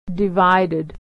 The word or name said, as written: divided